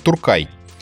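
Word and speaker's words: Türkei: Забавно, что Турция по-немецки а, пишется как Туркеи. То да, есть не а... ИЕ, а ЕИ. Читается как Туркай.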